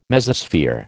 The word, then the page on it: Mesosphere: American English